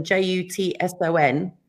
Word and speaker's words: Jutson: because my maiden name was spelt Jutson, J U T S O N, which was actually Dutch, and it's supposed to be supposed to be pronounced Jutson. But almost sounds like somebody's swearing at you.